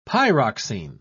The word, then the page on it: pyroxene: British English